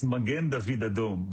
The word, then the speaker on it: Magen David Adom: So you got it right on the second time. It's Magen David Adom.